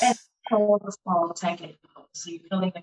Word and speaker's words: Teken: So in Egypt, it is called Tekken, which is spelled T-E-K-E-N. – Tekken.